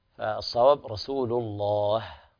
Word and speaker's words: رسول الله: وقال رسول الله، فطبعا هنا عدم همس الهاء يخرجها عن النطق الصحيح، فالصواب رسول الله ويهمس الهاء بهذه الطريقة.